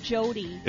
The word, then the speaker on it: Jyoti: Yeah, I think it's pronounced Jyoti. – Is it? It's spelled J-Y-O-T-I.